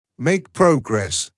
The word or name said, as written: make progress